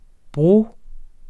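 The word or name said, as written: bro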